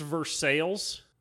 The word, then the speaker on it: Versailles: They're like, it's Versailles. All right, man, it's Versailles. It's spelled Versailles, like France, yeah.